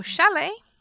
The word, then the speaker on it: chalet: But words like ballet, gourmet or chalet, ballet, gourmet or chalet, you don't pronounce them at all. So it's just an A sound, like holiday, but in writing it's ET, and you don't pronounce the T there. So some words with S-T-E-N, like listen, we're doing listening exercises, you're listening to a podcast, you're not listening to a podcast.